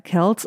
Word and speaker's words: Kelce: Aktuell könnten wir noch weiter über sie sprechen, das haben wir nicht getan, außer noch einmal richtig zu stellen, dass er Kelce ausgesprochen wird.